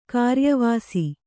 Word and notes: ಕಾರ್ಯವಾಸಿ: kāryavāsi

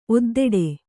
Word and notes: ಒದ್ದೆಡೆ: oddeḍe

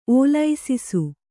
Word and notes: ಓಲೈಸಿಸು: ōlaisisu

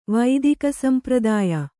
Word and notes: ವೈದಿಕಸಂಪ್ರದಾಯ: Kannada

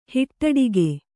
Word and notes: ಹುಟ್ಟುಡುಗೆ: huṭṭuḍuge

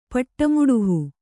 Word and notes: ಪಟ್ಟಮುಡುಹು: paṭṭa muḍuhu

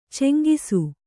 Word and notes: ಚೆಂಗಿಸು: ceŋgisu

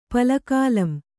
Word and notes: ಪಲಕಾಲಂ: kannada

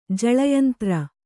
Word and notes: ಜಲಯಂತ್ರ: jala yantra

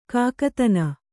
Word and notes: ಕಾಕತನ: kannada

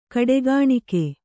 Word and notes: ಕಡೆಗಣಿಕೆ: kaḍegaṇike

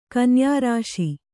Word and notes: ಕನ್ಯಾರಾಶಿ: Kannada

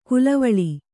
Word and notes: ಕಲ್ವೊಳೆ: kalvoḷe

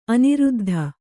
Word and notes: ಅನಿರುದ್ಧ: aniruddha

